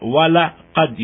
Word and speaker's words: وَلَقَدْ: And the second assertion is the lam itself in laqad wala qad. You hear the pronunciation wala qad.